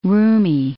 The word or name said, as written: roomy